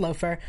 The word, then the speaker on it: Laufer: So in the chat role, we have somebody pointed out that there's the Loafer group, which is spelt Laufer, but according to GrimWiki, is pronounced Loafer, which I believe is actually the Resistance, is what they're called.